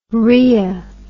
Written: rrhoea

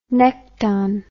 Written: necton

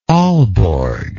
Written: alborg

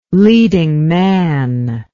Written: leading man

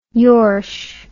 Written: yorsh

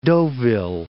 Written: Deauville

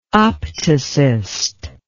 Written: opticist

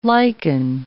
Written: liken

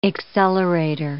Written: accelerator

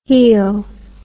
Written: hele

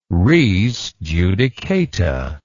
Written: res judicata

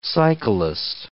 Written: cyclist

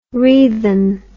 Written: wreathen

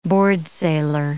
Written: boardsailor

